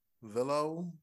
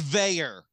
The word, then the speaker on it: Veilleux: It's spelled V-E-I-L-L-E-U-X. – Velo? – That's a good guess. – Gotta be French. – It's Veyer. I don't get it either, but it's Veyer.